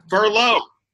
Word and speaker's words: furlough: Furlough. It rhymes with Merlot, as in what you can drink at 10:30 a.m.